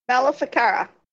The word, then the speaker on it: Maleficara: Maleficara.